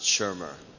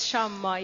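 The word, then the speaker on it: Shammah: I always want to pronounce it Shermer. – Mert szeretném Shermernek kiejteni, de hát ez Shamma itt mégis.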